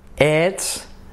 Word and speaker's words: it's: It's a folder. Folder, letras claves, tenemos letras claves, tres letras claves, la I como una E de it's, it's, y luego la L de folder y la R de folder.